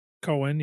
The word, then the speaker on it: Cohen: I'm not going to go through it all from what I remember, but Sacha Baron Cohen, or am I saying his name right? – Cohen, yeah.